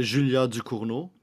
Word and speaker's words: Ducournau: Uh, um, yeah, uh, Julia Ducournau.